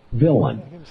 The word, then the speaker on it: villein: So if you go to Black's Law Dictionary, it's spelled, it has an unusual spelling because it's French in origin, but we say it villain. It's spelled V-I-L-L-E-I-N and pronounced in that spelling, villain.